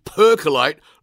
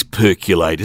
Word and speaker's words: percolate: The word you are looking for is percolate, not percolate. – What do you mean? – I mean you didn't pronounce that word properly, and it would be a shame if no one picked you up on it. – It is percolate, isn't it? – No, there is no U in that word, Studley. It's spelt with an O, percolate.